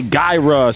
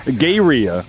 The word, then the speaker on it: Gaerea: So some people say gyra, some people say gyria. I'm going to say gyria. Who knows? G-A-E-R-E-A.